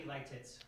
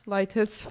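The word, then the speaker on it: Lititz: Do you know how to pronounce that? – Yeah, that'd be Lightitz. – Lightitz, Pennsylvania, for the F&M Spring Invitational at Bent Creek Golf Course.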